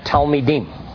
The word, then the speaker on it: talmid: Now, these rabbis with smicha were the ones who had talmidim. Now, I got to teach you a new word. I love the word. Talmid. Say talmid. Talmid. Say talmid. – Talmid. – Say it like you mean it.